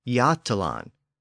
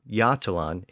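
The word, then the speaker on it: Götaland: The southern portion of the actual Scandinavian peninsula is called Jutland, and it's spelled G-O-T-A-L-A-N-D, which reflects an earlier pronunciation with an initial G sound, so it would have been more like Gotland. But there's also a large island to the east of the peninsula called Gotland, spelled G-O-T-L-A-N-D. So even though the names of the two regions are pronounced differently today, they have almost identical spellings.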